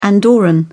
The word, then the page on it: andorran: British English